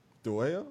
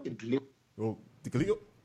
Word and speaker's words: Dhliwayo: Dhliwayo? Dhliwayo. – Say it again. – Dhliwayo.